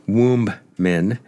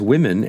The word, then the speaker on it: women: The plural as written seems to be womb men, but we pronounce it as women, and so it ought to be written.